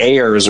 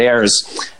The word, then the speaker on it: Ayres: I think I was saying Ayers or something. And then we corrected, it's David Ayers.